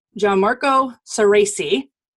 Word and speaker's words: Gianmarco Soresi: And my guest today is Gianmarco Saraci.